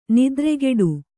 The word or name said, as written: ನೆತ್ತರುಗೊಡೆ